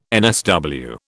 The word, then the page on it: NSW: British English